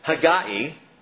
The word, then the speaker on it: Haggai: Haggai, which is actually Haggai. If you want to say it correctly in the Hebrew, it's Haggai.